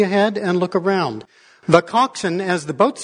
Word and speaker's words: coxswain: It's spelled coxswain. It's pronounced coxswain.